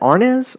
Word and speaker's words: Arnaz: Is it Arnaz? – I believe that's correct.